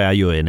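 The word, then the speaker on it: ajoene: Si scrive con la J, AJOEN, però è AION.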